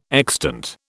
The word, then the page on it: extant: British English